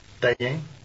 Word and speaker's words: têm: têm... – has the circumflex accent over the E and is pronounced differently.